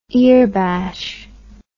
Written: earbashing

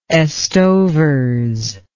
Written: estovers

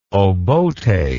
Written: obote

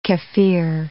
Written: kefir

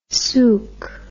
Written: sook

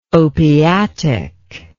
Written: opiatic

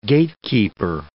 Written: gatekeeper

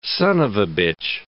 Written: son of a bitch

